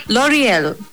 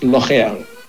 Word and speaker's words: L'Oreal: L'Oreal. I've said that right. – Almost. It's L'Oreal. – L'Oreal.